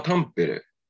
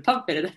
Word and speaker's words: タンペレ: 最初なんですけど、えっ、ー、と、タンペレ大学。発音というか、イントネーション合ってますね。 – イントネーションは面白かったです。 – タンペレ大学。